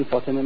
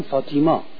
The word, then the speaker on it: فاطمه: اینا نمیتونن بگن فاطمه، میگن فاطیما.